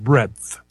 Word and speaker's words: breadth: No, I actually called because you said Breadith. – Oh, Breadith. Well, that's how it's spelt. I've never seen that word before. I don't know what to tell you. – I'm sorry. It's like the width and breadth.